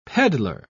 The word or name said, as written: peddler